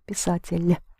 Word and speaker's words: писатель: Make sure you pronounce писатель soft in the ending. Писатель.